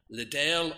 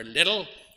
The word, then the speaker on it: Liddell: Eric Liddell, or Little, depending on how you want to pronounce it.